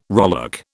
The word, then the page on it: rowlock: British English